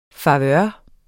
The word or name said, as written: favør